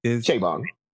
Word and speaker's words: Chabon: And I can't blame Michael Chabon this time, or Chabon, however you pronounce his name. – Chabon. – Whatever.